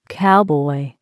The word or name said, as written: cowboy